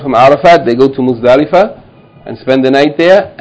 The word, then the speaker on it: Muzdalifa: Muz. Dalifa, Muzdalifa.